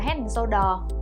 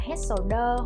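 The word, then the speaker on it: Head & Shoulders: khi mà người ta không đọc là Head and Shoulder mà sẽ đọc là Head Shoulder hoặc là Head Soda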